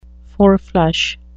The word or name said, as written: four-flush